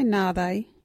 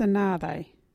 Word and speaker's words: nhaathayi: Our local Wadjuri word is nāthe. What is nāthe? Nāthe is when one cannot remember the proper name for something. You can say nāthe in this sentence. Where is the nāthe? Or, you know, nāthe. Nāthe is spelt N-H-A-A-T-H-A-Y-I.